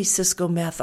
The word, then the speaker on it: Ciscometh: I am the only Cisco Meth on the internet, which is a beautiful thing.